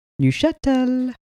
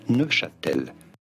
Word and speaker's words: Neuchâtel: The town where the school is called Neuchâtel. Neuchâtel is more how it would be pronounced. There's a beautiful sing-songy. – In French, it is said as Neuchâtel, Neuchâtel, meaning in old French, Newcastle, Neuchâtel, Neuchâtel.